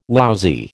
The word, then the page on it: lousy: American English